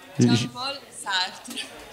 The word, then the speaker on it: Jean-Paul Sartre: Jean-Paul Sartre, ragazzi. – Però l'hai pronunciato malissimo, Raffi, te lo dico, Dillo tu, dillo tu. No, dillo tu, almeno la pronuncia è giusta. Jean-Paul. – Jean-Paul Sartre.